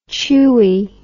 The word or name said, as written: chuey